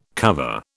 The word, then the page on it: cover: British English